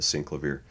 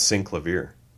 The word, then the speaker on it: Synclavier: At least that's how Henry pronounces it, the synclavier. – Hey, wait, is it synclavier or the synclavier? – It's synclavier. Stop trying to get street cred.